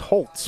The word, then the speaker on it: Holtz: It says it's pronounced Holtz with a T.